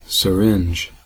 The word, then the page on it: syringe: American English